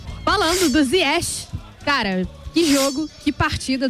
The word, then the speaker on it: Ziyech: A gente tem a pronúncia Ziyech, marroquina.